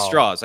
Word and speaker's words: Strauss: But the thing that I eh, that I was like, oh yeah, that's like following was uh, we find out that Strauss is that how I said that I pronounced straws. that correct? Yeah, it's like straws.